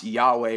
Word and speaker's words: Yahweh: which is it's pronounced Yahweh, which is I am.